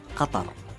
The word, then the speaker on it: Qatar: The official pronunciation of my country is Qatar.